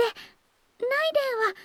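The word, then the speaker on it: 雷電: そして、ライデンは雷に電気と書いて、ライデンと読みます。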